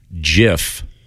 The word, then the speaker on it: GIF: But he stated categorically in an interview more than a decade ago, it is definitely pronounced GIF.